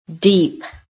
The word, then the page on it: DEEP: American English